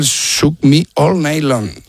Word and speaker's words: Shook Me All Night Long: Me cuesta el Shook Me All Night Long. – Ahí mucho mejor.